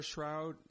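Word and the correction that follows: Shourd: Shord, I mispronounced her name, Shord, is, if you remember a few years ago, three hikers accidentally went into, I think it was Iran.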